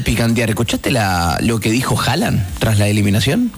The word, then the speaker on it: Haaland: Ah, escuché a noruegos decir que no es Haaland, es Haaland. Con O. Se parece a una O tipo aspirada, Haaland.